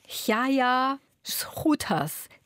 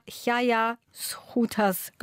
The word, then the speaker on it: Gaea Schouters: So in etwa spricht man die Autorin aus, eine flämische Autorin. Es schreibt sich Gaea Schouters, falls jemand das Buch im Buchhandel sucht. Es wird in etwa Chaya Schouters gesprochen.